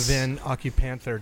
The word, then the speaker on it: Van Occupanther: On the Trials of Van Occupanther, did I say it right? – Yes, you did.